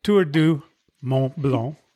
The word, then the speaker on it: Mont Blanc: And I'm going to give it the English pronunciation for the moment. I can try it. Tour du Mont Blanc en français. – Sure. – Mais aujourd'hui en anglais.